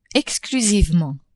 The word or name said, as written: exclusivement